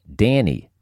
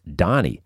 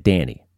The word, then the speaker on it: Dhani: George Harrison's kid is named Danny, D-H-A-N-I, pronounced Danny. You could go with something like that. I always assumed it was pronounced Donnie, but then I heard Paul McCartney say it, and he said Danny.